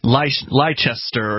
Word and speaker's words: Leicester: It looks like Leicester or Leicester or something like that, but it's actually apparently just Leicester.